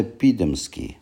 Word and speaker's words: Пидомский: Кстати, фамилия Пидомский, ударение на первый слог, не польского, не еврейского и не дворянского происхождения.